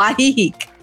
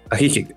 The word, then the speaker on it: Ajijic: ajijic, y corrígeme cómo se pronuncia eh, bien, ¿no? Ajijic o ajijic. – Ajijic.